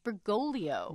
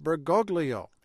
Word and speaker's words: Bergoglio: As a man whose parents emigrated from Italy, he has an Italian name, there pronounced Bergoglio. – But in Argentina, people commonly pronounce it Bergoglio.